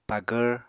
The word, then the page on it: pagvr: Apatani